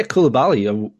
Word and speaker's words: Koulibaly: But is it Koulibaly? – Yeah, Koulibaly. Well done.